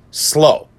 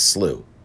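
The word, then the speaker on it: Slough: And somehow the judge, and I apologize in advance if I mispronounce his name because I've asked around, S-L-O-U-G-H. I'm told it's pronounced Slow. He used to be a crown attorney, Donald Slow. If I got the pronunciation wrong, please let me know. I'm not mispronouncing it deliberately. But I don't think it's Slew.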